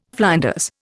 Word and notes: flinders: Indian

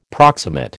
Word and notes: proximate: American English

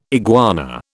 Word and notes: iguana: British English

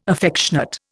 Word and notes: affectionate: Indian

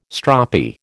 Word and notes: stroppy: American English

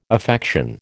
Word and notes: affection: American English

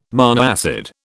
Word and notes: monoacid: British English

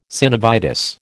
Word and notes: synovitis: American English

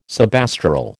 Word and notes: subastral: American English

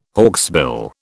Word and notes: hawksbill: British English

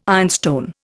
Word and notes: ironstone: Indian

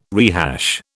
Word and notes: rehash: British English

